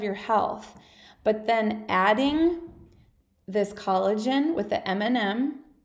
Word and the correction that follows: NMN: now I said it wrong, NMN,